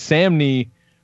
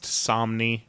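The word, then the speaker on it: Samnee: Love this fucking Chris Samney promo art. Holy shit. – It's pronounced Somni.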